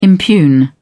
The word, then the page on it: impugn: British English